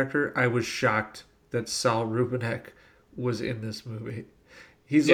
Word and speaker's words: Saul Rubinek: They work at a deli together, the deli is owned by Saul Rubinek.